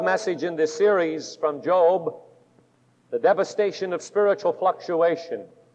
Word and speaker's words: Job: And it is spelled Job, but it's Job.